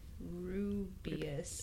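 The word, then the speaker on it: Rubaeus: So commercial examples are Bell's Cherry Stout, Dogfish Head, Apra Hop, Abelum Elderberry, Black Ale, and Founders Rubius. I don't think that's how I say it. – No, that's exactly how you say it.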